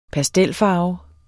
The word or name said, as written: pastelfarve